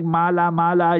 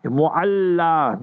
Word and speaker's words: Mu'alla: If you know Makkah Mukarrama well, first of all, learn to pronounce the word properly. Our people make mala mala, you know, like in Afrikaans, mal mal means you crazy, like, you know. So not mala mala, Mu'alla.